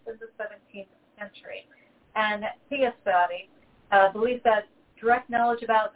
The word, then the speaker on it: theosophy: And that is Theosophy. That was not a mispronunciation of philosophy or theology. It's theosophy.